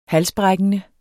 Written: halsbrækkende

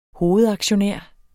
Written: hovedaktionær

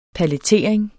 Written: palletering

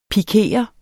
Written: pikere

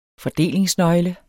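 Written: FORDELINGSNØGLE

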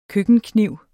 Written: køkkenkniv

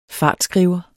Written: fartskriver

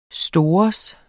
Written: stores

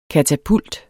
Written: katapult

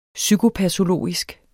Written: psykopatologisk

